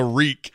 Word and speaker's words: reek: Reek, it rhymes with meek.